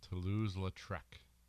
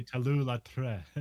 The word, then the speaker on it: Toulouse-Lautrec: How do you say his name? This is John Leguizamo's character. – Toulouse-Lautrec. Henri de Toulouse-Lautrec. – I think you say Toulouse-Lautrec.